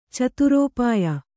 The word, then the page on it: ಚತುರೋಪಾಯ: Kannada; caturōpāya